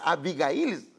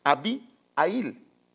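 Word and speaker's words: Abihail: El padre, o sea, el padre que era abuelo de, no es Abigail, pareciera que es Abigail, pero no es Abigail, sino es Abiail. O sea que es la H, es muda, o algunos también la pronuncian.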